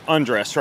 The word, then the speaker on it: Andres: So you say it's like, un- undress, right? Like undress, like take off your clothes.